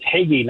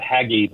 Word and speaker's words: Hagee: It's Hagee, not Haggy, by the way.